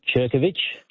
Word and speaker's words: Ciurcovich: Just on that name pronunciation, I had a school friend whose name was spelled C-I-U-R-C-O-V-I-C-H, pronounced Cherkovich.